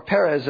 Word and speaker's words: Perez: and it gets to a descendant of Perez, or Perez as they pronounce it in Hebrew.